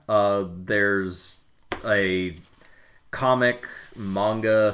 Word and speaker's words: manga: Surprisingly enough, you pronounce manga correctly.